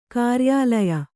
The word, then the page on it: ಕಾರ್ಯಾಲಯ: kāryālaya